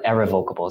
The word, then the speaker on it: irrevocable: Trusts back then were always irrevocable, or irrevocable is the more proper way to say it.